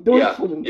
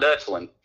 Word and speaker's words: Deutschland: I will tell you that Bundesrepublik Deutschland is yes! – German. – I was right. We need to go play some Trivial Pursuit, baby. Deutschland.